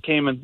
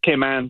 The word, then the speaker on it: Cayman: I had it's not Cayman. It's Cayman, but anyways. – Is it really? Is it pronounced Cayman Islands? – Yes, Cayman Islands.